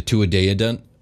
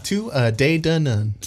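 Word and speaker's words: Tuatha Dé Danann: and also the opponents of the Tuatha De Danann. – Tuatha De Danann. – Oh, God, I've said it wrong twice now. Tuatha De Danann.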